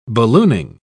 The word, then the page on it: ballooning: British English